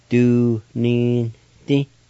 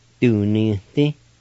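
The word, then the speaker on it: Duninidi: The second I will be silent in this word. You just pronounce the N sound like this, and that's nasalized like this. Du-ni-ti. Du-ni-ti, du-ni-ti. You just kind of slip in that N sound right in there.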